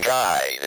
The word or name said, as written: guide